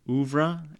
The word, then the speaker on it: oeuvre: James Farson said, a uniquely depressing part of his oeuvre. Is that right? Am I saying that right? – I'm sure, oeuvre. Yes.